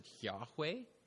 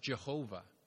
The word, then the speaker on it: YHWH: Some will pronounce it Yahweh, and others Jehovah.